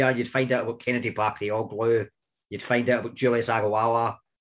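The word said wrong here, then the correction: Aghahowa: I didn't pronounce that right, Agahawa.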